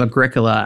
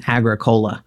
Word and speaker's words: Agricola: So, and that's how you say his name, Agricola. I was in a class in undergrad, and a guy kept calling him Agricola. And my professor had to kindly remind him he was not a carbonated beverage, you know.